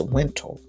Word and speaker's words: Wintle: And it's by Walter, I believe his name is pronounced Wintle.